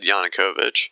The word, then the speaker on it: Yanukovych: Let's see if I can pronounce this. Yanukovych? – Yeah. – Yanukovych? – I've heard it said Yanukovych.